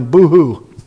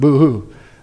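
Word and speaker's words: bohu: Void, the Hebrew word, not mine, this way they say it's supposed to be pronounced, not mine, boo-hoo. Okay? You can look it up, brethren. You can look it up, okay? It's the Hebrew word. We would spell it B-O-H-U-W, but they claim it's pronounced boo-hoo.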